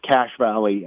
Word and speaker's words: Cache Valley: It's pronounced Cache Valley. The word cache in it, it's spelled different. It actually comes from the French trappers, cache, where they used to hide their their supplies, and it was called a cache.